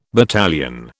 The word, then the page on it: battalion: British English